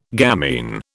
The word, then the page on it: gamine: British English